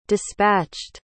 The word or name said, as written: dispatched